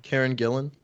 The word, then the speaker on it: Gillan: Brie Larson was in Avengers Infinity War with Karen Gillan. Or Gillian? – I'm not sure how to Gillen. Say her name either. But yeah. – Karen Gillan.